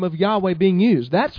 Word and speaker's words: Yahweh: Now, we know that the Father's name is Yod, He, Vav, He, pronounced Yahweh.